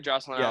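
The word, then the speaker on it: Jocelyn Alo: Well, I was going to say Jocelyn Al, or that's how you say it, right, Jocelyn Al? – Yes, yes.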